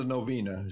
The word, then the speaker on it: novena: So after the novena, is that how you say it? – Yes.